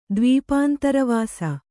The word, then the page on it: ದ್ವೀಪಾಂತರವಾಸ: Kannada